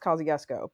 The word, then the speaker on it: Kosciusko: and it's named after the it's named after the like Polish American Revolutionary War general Kosciuszko, but it's pronounced Kosciuszko because of course, course it is.